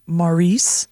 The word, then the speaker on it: Maurice: Saint, where I went to school, Saint Morris, home of Mike Clark, Channel 4 fame. – Okay. – Pretty sure it's Maurice. M-A-U-R-I-C-E.